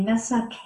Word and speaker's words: Mesa: And Kelly is an energy practitioner, astrologist, Akashic Records reader, and she's also a Mesa carrier. Mm-hmm. Did I say that correctly, Kel? – Yeah, you did.